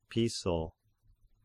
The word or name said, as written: Piso